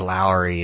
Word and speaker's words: Lowry: And the correct pronunciation is Lowry. That's how you pronounce Mike Lowry.